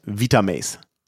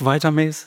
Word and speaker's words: Vitamaze: So würden es die deutschen Kunden sozusagen auch aussprechen. Inzwischen, seit wir Teil unserer internationalen oder europäischen Gruppe sind, fällt dann doch auch oft der Begriff Vitamaze. So, wenn ich es dann mal auch so ausspreche, dann sei mir verziehen.